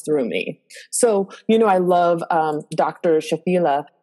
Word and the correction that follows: Shafali: Am I saying her name right? – Dr. Shafali.